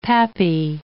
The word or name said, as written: pappy